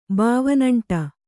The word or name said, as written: ಬಾವನಂಟ